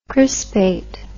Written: crispate

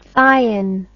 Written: thion

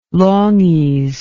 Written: longies

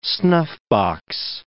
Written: snuffbox

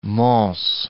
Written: Mons

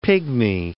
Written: pigmy

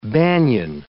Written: banyan

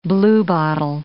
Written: bluebottle

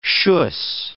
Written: schuss

